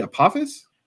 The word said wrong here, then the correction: Alpheos: Alpheos. It's Alpheos. The river. It's the river there, the river valley. That's what it's named after, the city.